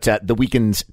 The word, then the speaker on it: The Weeknd: The first time, I'll say this about The Weeknd's talent. It is pronounced The Weeknd, by the way.